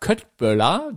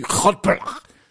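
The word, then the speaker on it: köttbullar: Aber jedenfalls hat die immer gesagt, Köttböller, so wie wir das aussprechen, wird's ja gar nicht ausgesprochen. Der Schwede sagt irgendwie, Köttböller.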